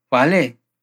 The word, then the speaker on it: vale: Ojo a esa V que aparece ahí al comienzo de la palabra vale. Hay muchos lugares en que la V se puede pronunciar como en portugués, pero los nativos van a pronunciarla mayormente como B.